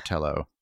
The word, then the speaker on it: Telo: Telo or Telo? – Telo. I say Telo, but Telo, Telo okay. Sounds good too.